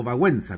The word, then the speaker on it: Wałęsa: Otro caso emblemático es el de Lech Wałęsa, o Wałęsa como se pronuncia en polaco,